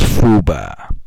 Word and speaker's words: FUBAR: A term often used by federal employees to describe the work situation is FUBAR, pronounced FUBAR. This